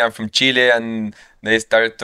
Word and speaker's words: Chile: How long did it take you living in the United States where you stopped getting annoyed that Americans called it chili? – What do you say? – Chili. You know, Americans say chili. They don't say Chile.